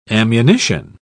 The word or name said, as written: ammunition